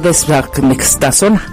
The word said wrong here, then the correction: dexametasona: Dexa. Dexametasona.